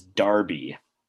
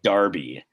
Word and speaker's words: Derby: but Derby, the correct pronunciation is Darby. Darby.